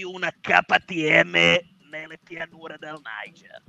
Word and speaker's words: Niger: Niger, lo pronuncio all'inglese, un rock un po' insolito, ma sempre rock con la sua chitarra del deserto, Bombino a Medellin.